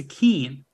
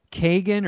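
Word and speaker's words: Keoghan: It's K-E-O-G-H-A-N. And I think it's pronounced Keane. Like, my brother told me it. – Keane? – Said that. – I've always said, like, Kagan or Keegan, but that was just a guess.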